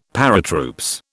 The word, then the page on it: Paratroops: British English